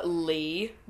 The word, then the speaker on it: Leigh: Lee, but it's spelled L-E-I-G-H for no fucking reason.